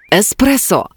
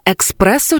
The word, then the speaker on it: еспресо: Еспресо, а не експресо чи експресо.